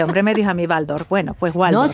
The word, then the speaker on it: Waldorf: He hablado con un amigo alemán y me ha dicho que se pronuncia Waldorf, aunque se escriba con W y nosotros tengamos esa tendencia. – No, es Waldorf, si es alemán es V, la W es V.